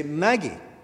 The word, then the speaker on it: magi: If we want to be true and correct to the pronunciation, to the plural form of magos, we should say Maggie.